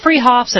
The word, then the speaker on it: Freehoff's: Spelled Freehoff's, pronounced Freehoff's at 33rd and Arapahoe in Boulder.